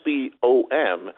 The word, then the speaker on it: SBOM: Okay, now the SBOM sounds like it's something very, very minor. It's pronounced SBOM, the Software Bill of Materials, and it's literally a bomb in cybersecurity because it redefines everything we've done in cybersecurity.